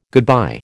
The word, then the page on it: goodbye: American English